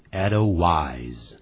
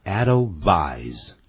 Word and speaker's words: Edelweiss: 这首曲子咱们中文呢把它翻成小白花，其实它是一个德文，这个德文呢不注意可能会念成 Edelweiss，那个 W 不能发成 W 的音，而要发成 V 的音，所以它实际的念法叫 Edelweiss， Edelweiss 小白花。